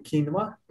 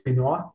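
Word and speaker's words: quinoa: Man bija uh, kinva salāti, kinva, pareizi izrunā kinva, nevis kino, jā, bet kinva salāti un cēzara salāti bez tiem tur gabaliņiem.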